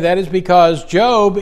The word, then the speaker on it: Job: Turn to the book of Job, if you would, please. It's spelled the same way as Job, J-O-B.